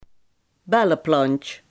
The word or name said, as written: fuchsia